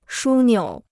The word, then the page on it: 枢纽: shū niǔ